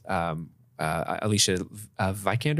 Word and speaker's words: Alicia Vikander: And then the Gollum character, which is Alicia Vikander.